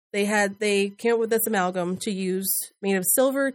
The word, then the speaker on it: amalgam: And then a dental alma. I don't know how to say it. – Amalgam. – Yeah. Amalgam. Yes. Thank you.